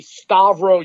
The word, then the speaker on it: Stavros: Actually, to be proper, it would be Stavro Giannopoulos. – Oh, the S is silent there, too? – Only one of them.